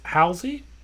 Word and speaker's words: Halsey: Track four is Halsey.